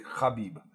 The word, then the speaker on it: Khabib: I know it's not Khabib though. We say it's like Khabib. KH is like they're like a French R, you know.